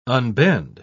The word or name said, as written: unbend